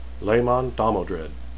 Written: laman

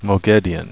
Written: moghedian

